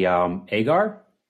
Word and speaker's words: Agar: And our next guest today is Carly Agar, if I'm saying that right.